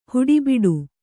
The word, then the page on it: ಹುಡಿಬಡಿ: huḍibaḍi